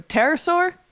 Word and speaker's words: pterosaur: It's a pterosaur. – No, that's I think but that's right pronunciation wise.